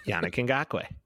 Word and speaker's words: Yannick Ngakoue: I was afraid to say his name because I still don't know how to say it. – Yannick Ngakoue. – There you go. The more you know. – That's how you say it. There you go, people.